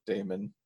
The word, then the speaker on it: Damon: You better learn how to say his first name if you want to be in his fan club. – How do you say it? Damon? Oh, I thought it was Damian this whole time. – Nope, Damon.